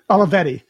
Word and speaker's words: Olivetti: I would love to have that Olivetti that you have. Is that the right way to pronounce it? Olivetti? – Olivetti. – That's right. Did you find that just on eBay, or did you find a private guy that was trading for it or something? – No, this was actually an eBay sale.